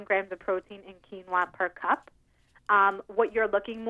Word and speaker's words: quinoa: So if I had a quinoa dinner, like just, is that how you say it? – Quinoa.